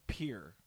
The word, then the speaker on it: Pierre: It's spelled Pierre, but it's pronounced Pierre.